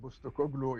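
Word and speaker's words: Postecoglou: يعني انت تشوف المدرب بوستوكوغلو يمكن اذا نطقت اسمه صحيح.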